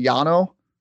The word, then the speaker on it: Llano: And if you were doing your Spanish pronunciation again, it would be Llano, which I guess the sister city of Amarillo or Amarillo.